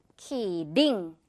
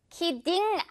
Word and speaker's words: kidding: 중간에 지금 이 t는 딩이라고 소리내면 좀 투박하다는 거. 키링. 리을 발음 납니다. 키링. 이거 무슨 뜻인지 아나요, 여러분? I'm just kidding. 이라는 말 정말 많이 하는데, 할게요. I'm just kidding. 키딩 아닌 키링.